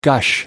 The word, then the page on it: gush: American English